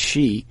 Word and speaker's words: Xi: I think and by the way, Eli Massey, managing editor of Current Affairs, been among those who corrected the way we were pronouncing the first syllable of the Chinese president's name, which we now is Xi.